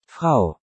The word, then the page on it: Frau: German